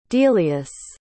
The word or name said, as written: delius